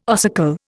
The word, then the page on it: ossicle: Indian